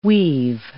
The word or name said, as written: weave